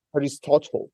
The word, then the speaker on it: Aristotle: How do you say in English, Aristotle? – Aristotle as in the Greek philosopher? – Yes. – Yeah, Aristotle, yeah. – Aristotle. I call it Aristotle, sorry. – Hey, mate, I'm Australian, so my accent is much worse than yours. I like the way that you pronounce Aristotle better than the way I do. – Oh, because it's the real way to do it. It's Aristotle, Aristoteles in Greek.